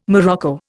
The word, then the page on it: morocco: Indian